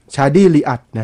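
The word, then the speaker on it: Chadi Riad: ชาดี้อ่านว่าลีอักใช่มั้ยต้องอ่านว่าอะไรวะชาดี้ลีอัดน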